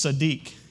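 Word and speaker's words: tzaddik (צדיק): And so I was looking this word up in the Hebrew, and it's tzaddik is the way it's pronounced.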